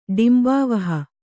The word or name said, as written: ಡಿಂಬಾವಹ